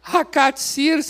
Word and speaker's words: Hakatsir: Hakatsir se escreve H-A-K-A-T-S-I-R. Hakatsir. O que significa Hakatsi?